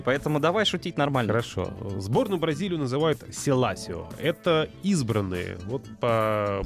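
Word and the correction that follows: Seleção: Селисао, правда. Селисао? Селисао. Хорошо, Селисао. Я просто вот э, смотрю мне вот читается Селасио.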